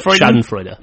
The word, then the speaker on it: Schadenfreude: Like I'm not going to say it correctly, but Schadenfreude. – Yeah, am I saying that right? – Schadenfreude. – Schadenfreude.